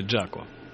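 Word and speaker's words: Jaco: Кстати, он нужно правильно произносить, как считаешь, Жако или Джако? Джако. – Ну, наверное, я не эксперт в плане произношения английского языка, но то, что я слышал, в принципе, он урожденный Джон. И тогда Джако.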